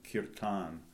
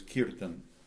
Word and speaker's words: kirtan: In America, we've changed the pronunciation to kirtan. – Yeah. – The original word is kirtan.